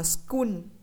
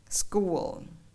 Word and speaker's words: school: hoặc là school đọc là school thì như vậy nó sẽ không đúng mà phải là school school